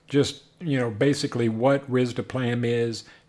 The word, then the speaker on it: risdiplam: Another drug, which is called, I'm probably going to butcher this, the pronunciation here, but I'm going to say risdiplam. Is that, I don't know. – It's actually pronounced risdiplam. – Risdiplam.